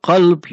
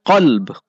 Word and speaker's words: qalb: And qalb, you know, we're not speaking of a kalb. Kalb, that's a dog. But qalb, qaf, every letter, how it's pronounced, everything preserved.